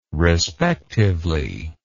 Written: respectively

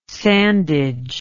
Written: sandage

